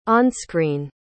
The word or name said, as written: onscreen